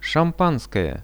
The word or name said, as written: shampanskoje